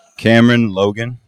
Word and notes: Cameron Logan: male

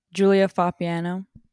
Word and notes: Julia Foppiano: female

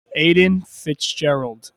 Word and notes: Aidan Fitzgerald: male

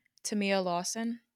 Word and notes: Tamia Lawson: female

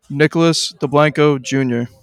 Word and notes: Nicholas Deblanco: male

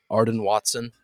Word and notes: Arden Watson: male

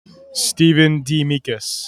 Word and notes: Stephen DeAmicis: male

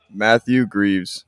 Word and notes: Matt Greaves: male

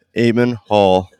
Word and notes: Eamon Hall: male

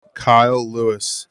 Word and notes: Kyle Lewis: male

